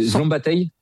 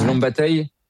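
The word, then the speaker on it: Szombathely: vous laisse le prononcer. – Alors, qui s'appelle, alors c'est Zlombattaï. – Voilà, c'est ça. – Zlombattaï.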